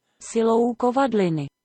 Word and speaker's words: Silou kovadliny: Salu Kovajlini. Kovajlini, Oh, man, I am terrible at trying to pronounce these. Google Translate says it translates to the power of the anvil, which is fucking cool. That's awesome. – Silou kovadliny. – There you go. That's what it sounds like in Czech.